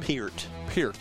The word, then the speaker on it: Peart: Peart. – Not. It looks like Pert. – I said Pert for years, but it's Peart.